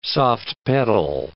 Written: soft-pedal

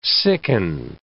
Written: sicken

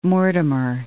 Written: Mortimer

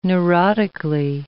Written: neurotically

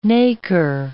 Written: nacre

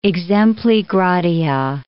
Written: exempli gratia

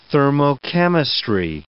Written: thermochemistry

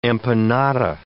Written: empanada

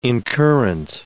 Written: incurrent

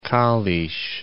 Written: Kalisz

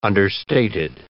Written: understated

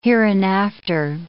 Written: hereinafter

